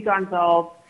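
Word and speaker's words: Gonsalves: My name is Judy Gonzalez. Last name is spelled G-O-N-S as in Sam, A-L-V as in Victor, E-S as in Sam. – Thank you. – It's Portuguese.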